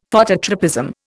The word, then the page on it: Phototropism: Indian